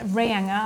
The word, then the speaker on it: Reinga: Reinga, I think you've got to pronounce it, isn't it? Yep.